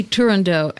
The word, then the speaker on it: Turandot: Turandot. – You're not saying it right. – Turandot? – Toronto. – Yes. – Okay. – Listen to him. – Don't hit that T and it's not a D.